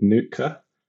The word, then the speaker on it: Nuitka: So actually, we use this library called Nuka, which is spelled Nuitka.